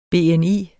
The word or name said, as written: BNI